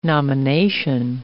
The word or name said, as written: nomination